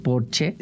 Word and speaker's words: Porsche: O decir que tengo un Porsche en lugar de decir tengo un Porsche, porque en alemán Porsche se dice Porsche porque todas las S al final se pronuncian, como Marlene Dietrich, por ejemplo, que todo el mundo dice Marlene, pero es Marlene.